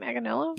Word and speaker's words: Manganiello: Manganiello. Is that how you say it? Manganiello? – Yeah.